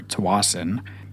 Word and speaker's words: Tsawwassen: Depending on where you are in the province, you pronounce it either Tsawwassen or Tsawwassen.